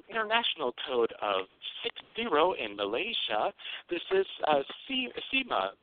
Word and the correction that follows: Simu: Hi, it's actually Simu, S-I-M-U.